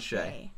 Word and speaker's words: Shea: John Shea. – Spelled like shea butter.